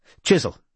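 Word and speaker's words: Chiswell: "Jasper Chisel, yes," said Robin, pronouncing the name as the Chiswells did themselves. "Chisel.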